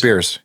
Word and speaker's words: Bierce: It's a fictional city in Ambrose Pierce. – Yeah. It's B-I-E-R. It's spelled like Pierce except with a B. – Oh, Ambrose Bierce.